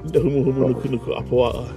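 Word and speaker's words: Humu humu nuku nuku apua (humuhumunukunukuāpuaʻa): So that's, in Hawaiian, that's how you say it. Humu humu nuku nuku apua.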